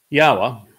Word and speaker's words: Yahweh: Yahweh, which is the proper pronunciation of Yod-Heh-Wah-Heh, he is our Eloah. The O sound from Eloah, God, is provided by the Wa, and it is concluded by the Ah sound in Yahweh's name, directed us to this pronunciation through his Torah guidance.